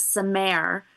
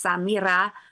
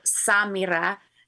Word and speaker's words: Samira: Until a Scottish friend of theirs, I think I was 3 months old, said, no, that's not actually how you pronounce that name. It's Samira or Samira. It depends on the inflection.